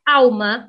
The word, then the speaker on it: alma: Por exemplo, o L é pronunciado como U na palavra alma no português brasileiro. Já no português europeu, se pronuncia de forma muito similar ao espanhol.